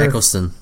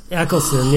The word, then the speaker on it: Eccleston: Christopher Eccleston. – Eccleston, yeah.